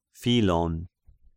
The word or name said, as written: Philon